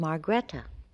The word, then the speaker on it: Margrethe: My Christian name is Margrethe, which is a Margaret, is the Norwegian Margaret. It's spelled M-A-R-G-R-E-T-H-E.